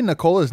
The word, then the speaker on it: Nikola: We all say Nikola's name wrong. It's Nikola, and it's not even Jokic. It's Jokic.